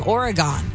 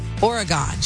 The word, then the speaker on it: Oregon: Also from Brittany, who says, I didn't know I was mispronouncing Oregon. She says, until my college friends teased me for it, I was saying Oregon, like polygon. – Yeah. – Oregon.